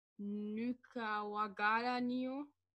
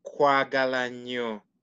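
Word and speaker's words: Nkwagala nnyo: okay, it's it pronounces as nkwa nkwagala. – Nyo. Kwagala. Neo. – Kwagala nyo.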